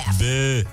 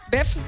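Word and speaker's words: boeuf: Și eu ce am zis, mă? Bef. Multă lume zice beof. La mine, când eram, eu tânăr, când eram tânăr copil, la mine era salată bef. – Bef? – Așa era, salată bef.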